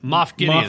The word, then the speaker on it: Moff Gideon: Versus, I think you'll find out like Goff, um, Goff Midian, um, am I saying that right? Moff Gideon. Moff sorry, Gideon.